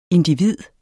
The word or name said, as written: individ